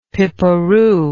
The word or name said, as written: pipperoo